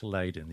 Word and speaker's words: Leiden: Well, it's Leiden, yeah.